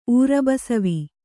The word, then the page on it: ಊರುಬಸವಿ: kannada; ūrubasav